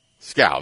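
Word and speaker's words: Skal: His first name is Scowl. – Scowl. That's it.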